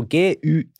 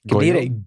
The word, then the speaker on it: Guiu: Og Det skrives jo GUIU. Altså. Mm. Man, kunne, man kan lett falle for fristelsen og si Goyo. – Guiu.